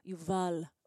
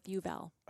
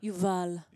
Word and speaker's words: Yuval: My dad's Israeli, so it's pronounced Yuval, but we'll go with Yuval. – Oh, wait. But say it how you say it, though. – Yuval. – Yuval. – It's just a heavier accent because that's how it's pronounced in Hebrew.